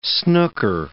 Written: snooker

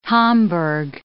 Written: homburg

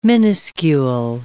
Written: minuscule

